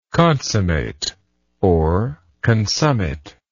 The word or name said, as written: consummator